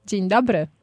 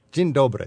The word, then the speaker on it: Dzień dobry: Dzień dobry. – Dzień dobry.